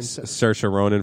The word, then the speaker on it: Saoirse Ronan: Saoirse Ronan for Little Women.